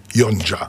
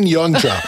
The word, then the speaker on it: Yonca: Ben Yonca. – Ja. – Wir kriegen euch. Irgendwo Ben Yonca. Y-O-N-C-A.